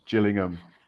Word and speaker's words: Gillingham: It's Gills in the blood because it's Gillingham.